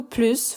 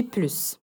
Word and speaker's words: plus: E quando que a gente não pronuncia o S de plus? A gente não pronuncia o S de plus quando for uma negação ou quando plus for expressar superioridade, seguido de um adjetivo ou advérbio.